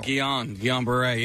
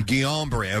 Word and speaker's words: Guillain-Barré: She said in the video, I'm dealing with something called Guillain-Barré syndrome. – Guillain-Barré, yeah. – Guillain-Barré, okay.